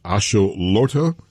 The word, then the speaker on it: Axolotl: It's the, for the World Cup, it is a salamander, and it's pronounced Axolotl. And it's I guess you would call it the mascot for the World Cup, but it has caused a bit of a problem. What is the controversy? – Yeah. Well, your pronunciation was kind of spot on, so no worries about that.